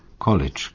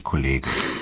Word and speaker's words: College: College, genau, nicht Kollege.